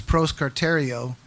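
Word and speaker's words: proskartereo (προσκαρτερέω): It's, well, the English pronunciation is proskartereo, and it's a word that shows up a few other places throughout Scripture.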